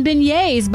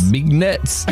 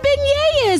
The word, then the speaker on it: beignets: B-E-I-G-N-E-T-S. – Bignets. – Bignets. I said, it is not Bignets.